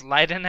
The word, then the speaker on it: Leiden: Leiden University. Did I say that right? – I think it's Leiden, actually.